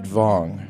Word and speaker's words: Wang: I only ask because my father's father was Norwegian, and his name was W-A-N-G, which means something totally different in Norwegian. – Absolutely, yes. – Yeah, and they pronounced it Vong.